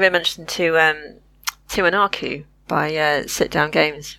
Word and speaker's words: Tiwanaku: Tiwanaku. T I W A N A K U.